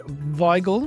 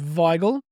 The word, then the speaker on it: Weigel: If you want to sound really fancy, make sure you always pronounce German-sounding W's as V's and everyone will think you're awfully fancy. So George Weigel, spelled with a W.